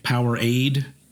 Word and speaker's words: Powerade: Power Rage. – Power Aid.